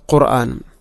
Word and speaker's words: ഖുർആൻ: ഇവിടെ ഖുർആന എന്ന പദത്തിൽ പ്രത്യേകിച്ച് നമ്മൾ ശ്രദ്ധിക്കേണ്ടത്, എപ്പോഴും നമ്മൾ പറയുന്നൊരു വാക്കാണത് ഖുർആൻ. നമ്മൾ പലപ്പോഴും ഖുർആൻ ഖുർആൻ എന്നാണ് പറയുക. അപ്പോൾ ഖുർആന ഖുർആന, ഖാഫിൻ്റെ ഉച്ചാരണമെങ്കിലും പ്രത്യേകിച്ചും ശ്രദ്ധിക്കണം, ഒരിക്കലും കാഫിനെ കൊണ്ട് പറയരുത്.